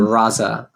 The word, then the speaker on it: Raza: Raza, R-A-Z-A.